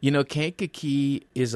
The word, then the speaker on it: Kankakee: He's gone to Kankakee, is that how you say it? – Kankakee, Illinois.